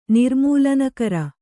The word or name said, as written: ನಿರ್ಮೂಲನಕರ